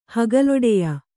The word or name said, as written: ಹಗಲೊಡೆಯ